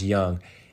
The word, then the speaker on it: Jung: And lastly, a little bit biased, but Josh Jung, J-U-N-G, spelled Jung, but pronounced Young, is taking on the third base role for the Rangers.